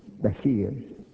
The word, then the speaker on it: Bashir: B-A-S-H-I-R, they spell it, but it's pronounced B-A-S-H-E-E-R, Bashir.